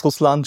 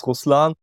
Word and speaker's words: Ruslan: And hey, Marty, my name is Ruslan Steiger, but I think it's pretty hard for the English people to pronounce Ruslan.